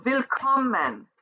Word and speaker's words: willkommen: So, willkommen, the syllable that is accentuated is that middle one. So, willkommen.